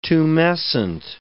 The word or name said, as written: tumescent